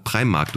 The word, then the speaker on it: Primark: Aber du, das ist sowieso immer auch noch, gerade Primark, du hast es richtig gesagt. – Ja. – Ne? Ich habe immer Markt gesagt, Primark. Mhm. Aber ist ja gar kein Markt.